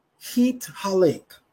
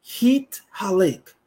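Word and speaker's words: Hit Halek (התהלך): The word we are going to look at is the second word, which is Hit Halek. Hit Halek. That's spelled Hey Tav Hey Lamed Kav Sufit.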